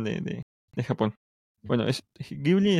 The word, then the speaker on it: Ghibli: ¿saben cómo se pronuncia en ¿sí, ¿sí conocen este estudio de animación japonés Ghibli? Las películas Ghibli. Sí, sí, sí, claro.